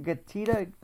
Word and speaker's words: Getida: By the way, am I saying it right, Yoni Mazor? Getida, G-E-T-I-D-A. – Yes, sir. You nailed it.